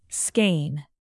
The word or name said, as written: skein